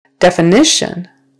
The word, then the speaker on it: definition: Definition, third syllable stress.